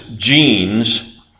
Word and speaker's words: Jeans: James Jeans, who was a British physicist, that's spelled just the way it sounds, just like the jeans you wear,